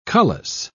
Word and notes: cullis: British English

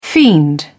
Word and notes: fiend: British English

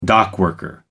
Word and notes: dockworker: American English

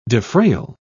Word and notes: defrayal: British English

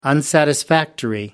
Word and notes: unsatisfactory: American English

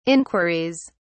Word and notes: inquiries: British English

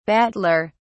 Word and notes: battler: British English

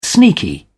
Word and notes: sneaky: British English

